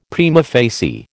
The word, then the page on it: prima facie: American English